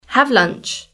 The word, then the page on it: have lunch: British English